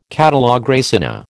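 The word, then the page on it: catalogue raisonné: American English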